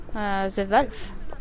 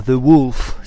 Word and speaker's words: The Wolf: Non, c'est pas très bien prononcé. On peut le prononcer de plusieurs manières, ça dépend. C'est plutôt The Wolf, si on veut. C'est plutôt un ou qu'un O, mais ça dépend un peu des accents.